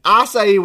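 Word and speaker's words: açaí: Talk to me about how bad acai bowls are for you. And I know I'm saying this on a big platform, and I'm probably saying it wrong. – I think you nailed that. Acai bowl.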